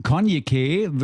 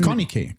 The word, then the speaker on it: Konyikeh: Konyke würde ich sie aussprechen. – Konyke. – Ja, da ist ja so ein Y, also K-O-N-Y-I. K-E-H, so wird es geschrieben, für die Leute, die es nochmal ganz genau wissen wollen.